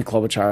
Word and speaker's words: Klobuchar: And I think it's different than, you know, people who aren't polling as well, but Tulsi Gabbard or Amy Klobuchar, and I know you don't like the way I say her name, but that's her name. – But that was actually the way you said it. – I know, you said it right this time.